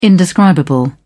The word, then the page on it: indescribable: British English